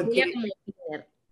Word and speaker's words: Wiener: Eh, en realidad siempre lo hemos pronunciado como Wiener.